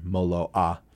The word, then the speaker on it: Moloa: There's also one from a company called Olukai that I really like called the Moloa. All their names are Hawaiian, so you have to do that like the Hawaiian pronunciation, Moloa.